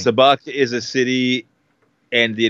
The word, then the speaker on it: Sabacc: No, it's it starts with an S, Sabacc. I hear people pronouncing it as Sabacc, but I'm pretty sure it's Sabacc, because I'm pretty sure they refer to it as Sabacc in Solo. If I remember correctly, they pronounce it Sabacc.